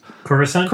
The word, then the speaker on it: Coruscant: Coruscant? – Coruscant, thank you. – You're welcome. That's why you have me here. Obscure Star Wars references and the occasional pronunciation.